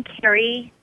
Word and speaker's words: Kerri: I'm on Facebook, and my name, Carrie LeBlanc, and I'll spell it K-E-R-R-I, and the last name is L-E, the letter B, as in the word blessings, L-A-N-G, as in the word gratitude.